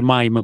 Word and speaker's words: meme: E, ehm, e, e, tendenzialmente eh, appunto Nell'ultima settimana eh, il, eh, che, continuo a chiamarli meme anche se andrebbero pronunciati mime perché proprio non mi viene.